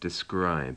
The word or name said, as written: describe